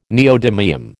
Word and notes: neodymium: American English

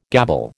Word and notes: gabble: American English

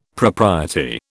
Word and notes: propriety: British English

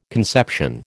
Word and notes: conception: American English

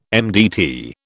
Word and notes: MDT: British English

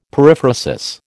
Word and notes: periphrasis: American English

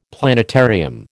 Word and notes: planetarium: American English

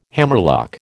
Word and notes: hammerlock: American English

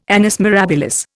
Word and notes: annus mirabilis: Indian

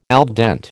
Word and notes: al dente: American English

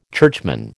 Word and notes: churchman: American English

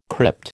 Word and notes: crypt: American English

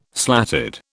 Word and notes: slatted: British English

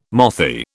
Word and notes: mothy: British English

